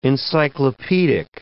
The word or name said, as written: encyclopaedic